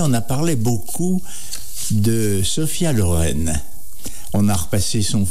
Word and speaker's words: Sophia: Je précise d'abord, son prénom se prononce Sophia, que ce soit avec un F ou PH, modernisé en PH. Et Sofia, c'est la capitale de la Bulgarie.